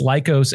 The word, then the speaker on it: Lycos: It's Lycos Airsoft, right? Am I pronouncing that correctly? – You are. It is it's Greek for wolf.